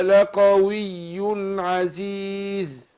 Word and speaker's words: لقوي عزيز: ما اقول لقوي، هنا النون التنوين هي تعتبر نون ساكنة اللي بننطقها مش بتتكتب، لقوي، النون دي جه بعدها العين، والعين من حروف الإظهار، حرف حلقي بيخرج من الحلق، فأنا بظهر النون عنده، لقوي عزيز. لقوي ده اسمه إظهار حلقي، خلاص؟ – تمام.